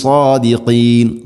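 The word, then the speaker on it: സാദിഖീൻ (صادقين): ഇൻ കുന്തും സാദിഖീൻ, സാദിഖീൻ, സാദിൻ്റെ ഉച്ചാരണം ശ്രദ്ധിക്കണം. രണ്ട് ചുണ്ടുകൾ തമ്മിൽ കൂട്ടി ഇങ്ങനെ റൗണ്ട് ഷേപ്പിൽ വരുന്ന രൂപത്തിലല്ല, ചുണ്ടുകൾക്ക് പ്രത്യേകിച്ച് അവിടെ വർക്കില്ല. സാദിഖീൻ, സാദ് ഹെവി ലെറ്ററാണ്, അഥവാ ഇസ്തിഇലാൻ്റെ അക്ഷരമാണ്. സ്വരഭാരത്തിൽ ഉച്ചരിക്കണം സാ സാ. നാവിൻ്റെ മധ്യഭാഗം അണ്ണാക്കിലേക്ക് ഉയർന്നു നിൽക്കണം. സാദിഖീൻ, ഖാഫിൻ്റെ ആ ഒരു മഖ്റജും ശ്രദ്ധിക്കുക, സാദിഖീൻ എന്നാണ് പറയേണ്ടത്.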